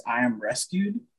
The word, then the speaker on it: IMRSQD: Just this dude, if you ever get a chance, his name is I Am Rescued, but it's spelled weird. It's spelled like I-M-R-S-Q-D.